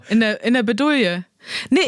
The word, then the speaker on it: Bredouille: Wie spricht man das? – Bredouille. – Bredouille.